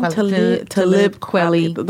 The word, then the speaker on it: Talib Kweli: Talib Kweli, the rapper.